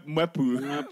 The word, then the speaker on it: Mwepu: Mperwa, bro. – Oh, Mperwa, you know. Like, that was so Ugandan. I think it's Mwepu.